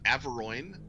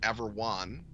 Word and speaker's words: Averoigne: I pronounce it Averoigne. – Okay. – Because I am a clod. – Sure. – And so that people recognize what I mean. I am told that, or rather I've read that Clark Ashton Smith pronounced it Averwan. In Steve Barron's book on Clark Ashton Smith, he gives the pronunciation Averone. – Okay. – So I think you're spoiled for choice. It's just like Cthulhu.